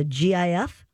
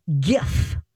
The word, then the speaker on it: GIF: Wanted to make the case that it is the one that owns the soft G pronunciation, while GIF should be said with a hard G.